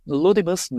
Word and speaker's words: Ludimus: What is Ludimus? Am I saying that right? – Yes. Ludimus means we play in classical Latin.